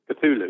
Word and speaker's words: Cthulhu: Cthulhu, yeah.